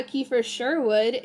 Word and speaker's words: Kiefer Sherwood: Yeah, Kiefer Sherwood. If you didn't know how to say that sign, that's how you say it.